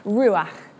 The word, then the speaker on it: ruach: The Hebrew word for the Holy Spirit or God's personal presence is ruach. And you've got to get a nice sort of back sound at the end of that word, which I love as a speech and language therapist.